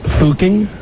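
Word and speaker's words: Fucking: It's spelled like the F word with ing. – Yes. – But it's not pronounced how you think it is. The pronunciation rhymes with looking. – Looking. So it's Fooking? – Yeah.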